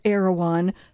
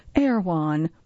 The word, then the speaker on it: Erewhon: Pronounced Erewhon, but in certain surfer dude lilts is reduced to two syllables, Erewhon.